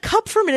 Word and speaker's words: Kupferman: Like Kupferman is impossible. Why? I don't know. It is said exactly how it's spelled. But my like the last 15 years have been Kupferman, Cooperman, whatever.